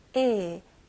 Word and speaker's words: ill: 네, 발음은 또 I 발음이 나왔네요. 네, ill, ill. 에도 아니고 이도 아니고 에입니다.